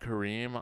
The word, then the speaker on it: Kareem: That's the standard one, at Kareem. On TikTok, I'm at Kareem Rahma, which is my last name, R-A-H-M-A.